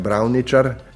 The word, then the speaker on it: Brauničar: Na Tolminskem imamo recimo priimek, ki ga pa tam sploh ni več, Brauničar, ki ga danes izgovarjajo Brauničar in je samo še v centralni Sloveniji zastopan, so ga pa v 19.